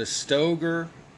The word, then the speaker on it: Stoeger: The Stoger, that's spelled S-T-O-E-G-E-R, STRF. Best mid-size gun. The Sig Sauer, S-A-U-E-R, P210 carry.